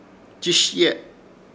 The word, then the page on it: Chiishye: Ishir Chamacoco